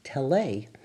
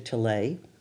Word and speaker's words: Tellez: T-E-L-L-E-Z. And I thought, because I studied French in high school, that it must be pronounced Tele. That's so I called the station, I think it was ABC, asking for Mr. Tellez, and finally somebody corrected me, and I got to Rudy Tellez, whose son, Stephen, became a very successful agent at CAA many years later.